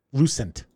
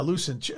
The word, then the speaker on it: Lucent: L-U-C-I-A-N? – Is it Lucient or Lucient? – Lucent. – Yeah, Lucent.